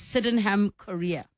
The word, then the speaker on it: Sydenham chorea: They said Sydenham chorea. I think that's how it's said. Yes, Sydenham chorea.